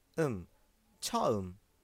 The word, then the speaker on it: 처음: برای اولین بار به زبان کره‌ای میشه چام چا ام از ته حلق تلفظ میشه ام چا ام.